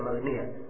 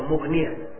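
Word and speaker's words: Mughniyah: Ya, dia babi kulit yang Magnia. Ya, apakah bacanya seperti itu? Kalau saya sendiri, ya, kelihatannya bagusnya dibaca Mugnia.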